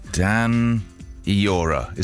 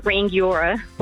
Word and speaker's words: Rangiora: Bangiora. Is that right? – Bangiora. If you're struggling with the Maori pronunciation, you can say Rangiora, the other locals.